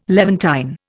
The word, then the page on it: Levantine: Indian